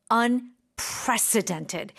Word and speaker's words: unprecedented: And the accent is on unprecedented. Precedented. Yeah. – Precedented. – And you really do need to stress that because what you're about to say is going to be, oh my gosh, I can't believe that.